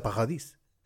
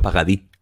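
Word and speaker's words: Paradis: Sí, ¿Has, visto, ¿Has visto cómo me esforzaron en decir Vanessa Pajadís? – Tenías que haber dicho pagadí, porque la S no se pronuncia, pero bueno.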